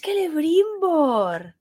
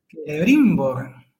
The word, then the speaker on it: Celebrimbor: No lleva tilde. Termina en consonante. ¡Ah! Termina en consonante. O sea que es Celebrimbor. – ¡Oh! ¡Ciertamente! – ¡Qué fuerte! ¡Es Celebrimbor! – Celebrimbor.